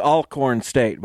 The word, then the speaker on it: Alcorn State: And I was told it's Alcorn State, by the way.